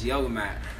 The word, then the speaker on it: Yoga Matt: My name is Yoga Matt. – Yoga Matt, man. How you spell that? – Y-O-G-A-M-A-T-T, and usually it doesn't, you don't say Yoga Matt without saying Yah.